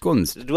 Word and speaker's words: Gunst: Mehr Infos hören Kunst. wir da. Gunst, Was habe ich denn gesagt? Kunst. Gunst. Du hast Kunst gesagt. – ich hab, das war, Gunst. ja, ich habe es ostdeutsch ausgesprochen.